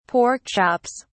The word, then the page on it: pork chops: British English